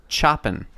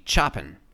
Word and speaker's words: Chopin: It's pronounced Chopin. – Hey, how do you pronounce G A R F I E L D? – Like chopping, but without the G. Chopin.